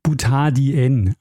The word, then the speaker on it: Butadien: Es heißt aber natürlich Butadien, also die Betonung auf dem N, und das Di steht für Lateinisch 2, deshalb.